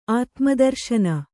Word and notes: ಆತ್ಮದರ್ಶನಿ: ātmadarśani

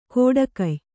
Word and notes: ಕೋಡಕಯ್: kōḍakay

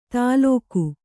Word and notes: ತಾಲೋಕು: tālōku

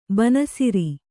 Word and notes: ಬಣ್ಣಸರ: baṇṇasara

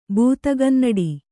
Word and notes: ಭೂತಗನ್ನಡಿ: bhūtagannaḍi

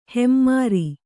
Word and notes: ಹೆಮ್ಮರ: hemmara

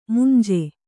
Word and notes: ಮಂಜು: manju

